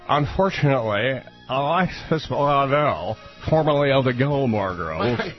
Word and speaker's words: Alexis Bledel: So, Alexis Bledel, I said it right. – You did it correctly.